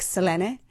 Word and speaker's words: Selene: I think probably the pronunciation is more like Selene. But we say Selene, and in fact, it's the first line of the show. We have a little tongue-in-cheek, twinkly eye chat about how we pronounce it.